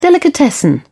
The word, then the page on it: delicatessen: British English